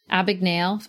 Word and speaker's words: Abagnale: I think of the Frank Abagnale. Am I saying that right? – Abagnale effect.